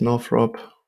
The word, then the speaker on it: Northrop: Wie spricht man die richtig aus? Northrop. Ja, stimmt.